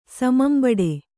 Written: ಸಮಂಬಡೆ

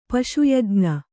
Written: ಪಶುಯಜ್ಞ

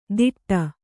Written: ಧಿಟ್ಟ

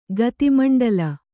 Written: ಗತಿಮಂಡಲ

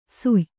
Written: ಸುಯ್

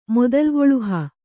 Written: ಮೊದಲ್ಗೊಳುಹ

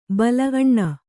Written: ಬಲ ಅಣ್ಣ

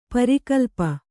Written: ಪರಿಕಲ್ಪ

